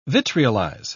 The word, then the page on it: vitriolize: British English